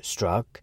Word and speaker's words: Strzok: In truth, anyone who watched Strzok, the name is pronounced Strzok, testify saw just the opposite.